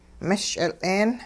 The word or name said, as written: mehsch'il'een